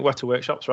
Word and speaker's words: Weta Workshop: Now, I apologize if I absolutely butcher the name, but I believe it's pronounced Weta Workshop. – Yeah, I think Weta Workshop's right. So they do like all of the...